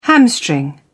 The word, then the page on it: hamstring: British English